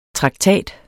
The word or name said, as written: traktat